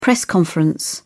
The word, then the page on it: press conference: British English